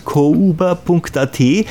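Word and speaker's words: kouba.at: Okay, also noch einmal die Webseite kuba.at, das schreibt man mit OU, also K-O-U-B-A, also kuba.at.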